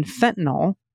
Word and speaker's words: fentanyl: The NYL in fentanyl rhymes with pill, not fall.